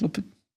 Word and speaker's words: Lopetegui: Der Trainer Lopetik Gau, äh, Lopetegui, wie spricht man das aus? Lopetegui, muss man sich gar nicht merken, ist eh weg.